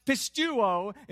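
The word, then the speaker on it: pistuo (πιστεύω): The Greek word pronounced pistuo is used here. The word believe.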